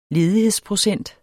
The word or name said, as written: ledighedsprocent